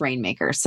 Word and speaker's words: RNMKRS: And the reason I mentioned that first is because Rainmakers, it's Rainmakers without the vowels is how it's spelled. So R-N-M-K-R-S, but it's pronounced Rainmakers.